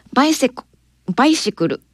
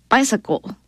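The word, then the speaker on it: bicycle: この曲のキャッチコピーはバイシクルのスペルが書けますかです。バイサコ、バイシクルっていいけどバイサコ感じかなという発音なんですけれども、バイセクルのスペルは BI、これバイね。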